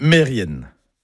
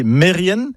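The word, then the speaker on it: Merien: Et puis un autre mot, par contre, qui lui n'est jamais bien prononcé, c'est Mérienne. Mérienne, comme ça ne vous parle pas. Mais si je dis Mérien, bah oui, vous allez reconnaître le nom de famille, Kermérien, le nom de lieu. Eh ben non, tout ça c'est faux, c'est Mérienne, Kermérienne. Et au passage, Mérienne, ce sont des fourmis.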